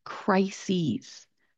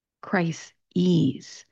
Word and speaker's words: crises: If you listen to the difference when I am pronouncing this, crisis, crises. There is a vowel change because the spelling of the plural form of the word ends in an ES.